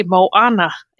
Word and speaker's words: Moana: M-O-M-O-A-N-A. Moana.